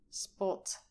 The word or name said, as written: spot